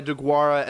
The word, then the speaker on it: Deguara: Am I pronouncing Deguara.